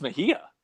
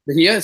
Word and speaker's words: Mejia: Do we have a consensus on how you pronounce Major? – Yeah, I think it's if you know this. – I think it's Mejia. – Mejia, so it is a soft J. – I believe so. Like yogging. – Like yogging, yes.